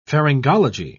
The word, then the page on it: pharyngology: British English